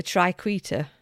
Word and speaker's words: triquetra: The triquetra. Triquetra, apparently, is how you pronounce it.